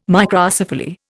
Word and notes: Microcephaly: Indian

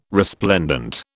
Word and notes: resplendent: British English

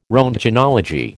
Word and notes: roentgenology: American English

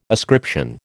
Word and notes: ascription: American English

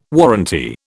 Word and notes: warranty: British English

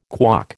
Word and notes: KWOC: American English

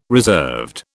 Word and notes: reserved: British English

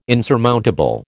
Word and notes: insurmountable: American English

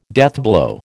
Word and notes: deathblow: American English

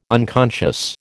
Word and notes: unconscious: American English

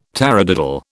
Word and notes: taradiddle: British English

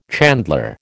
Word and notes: chandler: American English

